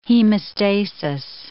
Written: hemostasis

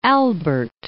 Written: Saint Albert

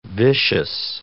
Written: vicious

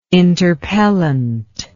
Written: interpellant

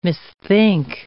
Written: misthink

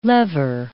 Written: lever

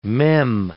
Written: mem

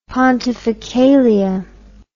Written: pontificalia

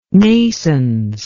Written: naissance

